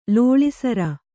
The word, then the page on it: ಲೋಳೆಸರ: lōḷe sara